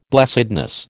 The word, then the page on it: blessedness: American English